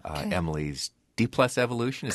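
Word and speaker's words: Emily's D+Evolution: So I want to start out by talking about your new album, Emily's D-plus Evolution.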